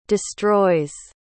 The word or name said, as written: destroys